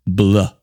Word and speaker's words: Bleu: Their brand new album, Hotel Bleu. I have to say it the French way because it's spelt the French way.